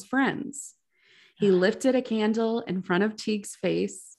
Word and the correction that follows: Tighe: Did I say Teague? – How do you spell it? – T-I-G-H-E. – Oh, that's a toughie. – I think it's Tig. I'm gonna say Tig.